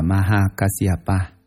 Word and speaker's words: Maha Kasyapa: Ngài Maha Ca Diếp, Ấn Độ đọc là Maha Kasyapa, chữ Hán là Đại Ẩm Quang.